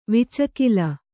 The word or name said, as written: ವಿಚಕಿಲ